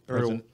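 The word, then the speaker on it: Erdoğan: Erdogan.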